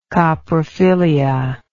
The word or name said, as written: coprophilia